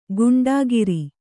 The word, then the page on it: ಗೂಂಡಾಗಿರಿ: gūṇḍāgiri